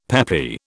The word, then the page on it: peppy: British English